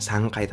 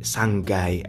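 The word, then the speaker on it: sanggai (三階): Lantai 3 misalnya bukan sangkai tapi sanggai ada yang seperti itu lantai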